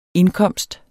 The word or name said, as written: indkomst